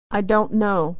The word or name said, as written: don't know